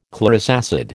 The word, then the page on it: chlorous acid: American English